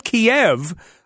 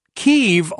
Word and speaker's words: Kyiv: And it says if you actually simplify things, it should actually have been pronounced not Kiev, but Kyiv all along, or so he has said.